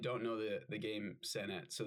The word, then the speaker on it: Senet: So, um, the oldest board game known to man uh, was is is I believe it's pronounced Senet. It's it's called Senet mm.